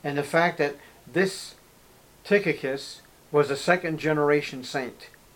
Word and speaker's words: Tychicus: So it's Tychicus.